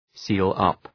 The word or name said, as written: seal up